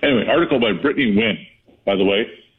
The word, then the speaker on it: Nguyen: It's spelled N-G-U-Y-E-N. I only know how to pronounce that because I had a coworker, but the same last name, pronounced it Nguyen with no N or G in the middle of the pronunciation.